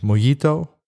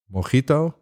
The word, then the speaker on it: Mojito: Ik dacht altijd dat het Mojito was. Ik heb ooit Spaanse les gehad. En dan leer je dat de J uitgesproken wordt als een G. Dus vandaar Mojito.